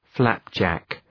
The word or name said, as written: flapjack